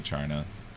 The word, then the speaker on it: Charna: I say Charna. I like to pronounce the hard CH.